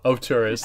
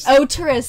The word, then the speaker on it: auteurist: He tackles straight story from an, how do you say that? Auteurist. – Auteurist, sorry.